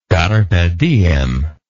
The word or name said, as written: carpe diem